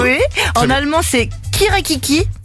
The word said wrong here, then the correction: Kikeriki: Non, kirekriki. Waouh, ouais, bon, voilà. – J'aime bien les infos à moitié. Bon, bah voilà, le truc, j'arrive pas à le prononcer. – Kikeriki.